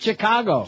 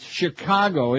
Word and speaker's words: Chicago: It's not Chicago. – Chicano. – Good golly, it's Chicago. It's Chicago.